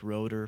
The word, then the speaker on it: Roeder: Yeah, Roeder. My last name is spelled R-O-E-D-E-R, spelled like Roeder, pronounced Roeder.